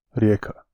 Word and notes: Rijeka: rijěːka

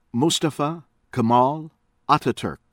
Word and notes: Mustafa Kemal Ataturk: American English; from Turkey

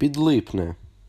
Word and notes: Підлипне: [pʲid̪ˈɫɪpn̪e]

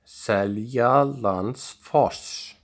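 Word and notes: Seljalandsfoss: Icelandic

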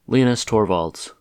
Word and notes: Linus Torvalds: American English; Midwestern accent

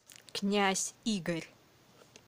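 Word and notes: Knyaz Igor: Russian

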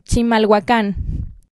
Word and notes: Chimalhuacán: Spanish

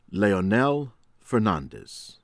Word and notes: Leonel Fernandez: American English

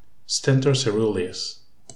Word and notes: Stentor coeruleus: English

